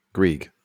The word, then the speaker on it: Greig: Is it Greg or Grieg? Grieg.